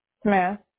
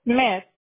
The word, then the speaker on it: Smich: Smith. – Perez? – Smith. – How do you spell that? – S-M-I-C-H. – Oh, got it. S-M-I-C-H.